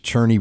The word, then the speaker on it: Cerny: Now, it looks like it's pronounced Cerny. The proper pronunciation is Cherney.